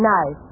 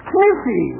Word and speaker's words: knife: K-N-I-F-E. – K-N-I-F-E? That's Kniffy. – No, the word is knife. K is the silent letter. K is always silent in front of N.